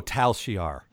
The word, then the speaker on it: Tal Shiar: Oh, Tal Shiar. – Yeah, okay. Yeah, because the way they pronounce it, it doesn't, you don't hear the L. It just sounds like Tasha Yar.